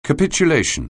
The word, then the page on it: capitulation: anglais